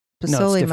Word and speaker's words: pozole: My wife makes awesome beef soup and pozole. – Mm. – Did I say that right? – Pozole, yes. Mm-hmm.